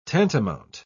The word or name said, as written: tantamount